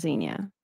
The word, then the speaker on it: Xenia: No, my name is Xenia. – Okay, cool. – But I go by X because nobody can pronounce my name, but the way that it's spelled.